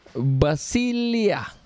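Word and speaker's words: basilia (βασιλεῖαι): It's hard to pronounce, but basilia. Okay, that's how it's pronounced, they said, Basilia, which means kingdoms or sovereignty or political entities.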